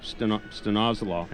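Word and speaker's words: stanozolol: we've seen four players fail drug tests for stenozolol.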